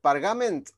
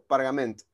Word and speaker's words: Pargament: ¿Pargament o Pargament? – Yo le digo Pargamen, pero está poco claro cómo era la, pronunciación original, así que por ahora Pargamen.